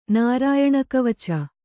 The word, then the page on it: ನಾರಾಯಣಕವಚ: Kannada